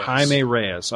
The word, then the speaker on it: Jaime Reyes: Jaime Reyes, all right.